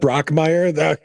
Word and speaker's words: Brockmire: Am I saying it right yet? I'll know. Yeah. I'm like, I'll know my head is like straightened out when I start saying that right. – You did say it right. – All right. Pass the test. All right. What movies have you seen lately? It's just Brockmire.